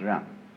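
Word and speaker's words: Ren (仁): In Confucius philosophy, the fundamental virtue of a human being is called Ren, spelled J-E-N for reasons best known to Chinese scholars. I don't know what they are, but it's pronounced Ren.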